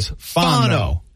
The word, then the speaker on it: Fano: Four letters, and there's been at least eight pronunciations of his name. – Okay, Fano.